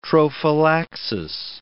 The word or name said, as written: trophallaxis